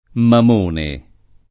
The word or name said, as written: Mamone